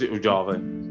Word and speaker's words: Uvalde: Uvalde, if I pronounced that right. – I believe it's Uvalde.